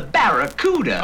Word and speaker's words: Barracuda: Hey, man, the name of the Plymouth Fastback is the Barracuda. I can't pronounce Baccaruda. Yeah, well, look, man, try this. Say back. ba Ra. Ra. Coo. Coo. Da. Da. Now put it all together!